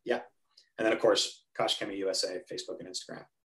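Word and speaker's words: Koch-Chemie: So technically the proper way is Koch and then Chemie, but they miss the K in the middle. So it's just, I always say Kosh Chemie because that's basically how they say it. Cause they just kind of skip that K sound in the middle.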